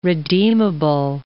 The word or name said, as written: redeemable